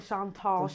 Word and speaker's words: Chantal: The Chantal Show.